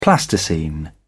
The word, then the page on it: plasticine: British English